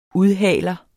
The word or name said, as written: udhaler